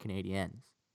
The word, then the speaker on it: Canadiens: So that is why it's like that, but that's actually how it's pronounced, Canadiens. – Yeah. – Like aliens, like I-E-N-S.